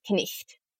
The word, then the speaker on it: knight: And you pronounce all of the letters in consonant clusters like KN and WR, as well as silent GHs. So the word knight was knicht, the reasons for the vowel shift are a bit debated.